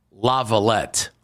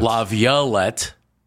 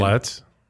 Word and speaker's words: Laviolette: Laviolette, written down as a pronunciation. – Laviolette. – Laviolette is the way it would look like because after the la, it's violet.